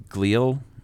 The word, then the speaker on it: glial: Specifically, the glial, G-L-I-A-L is how I'm pronouncing it.